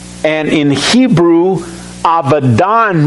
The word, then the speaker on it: Abaddon: And they had as king over them the angel of the bottomless pit, whose name in Hebrew is, and it's not pronounced Abaddon in Hebrew, it's pronounced Abaddon. When we see a B, if you pronounce it in Hebrew, it's pronounced like a V. Abaddon.